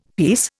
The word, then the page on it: piece: Indian